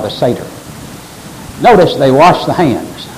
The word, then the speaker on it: Seder: That E in S-E-D-E-R is pronounced as a long A, like you say. Say, S-A-Y, say, Seder.